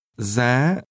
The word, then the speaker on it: giá: Một cốc bia giá bao nhiêu? Giá. Giá. – Cost is pronounced with a high rising tone.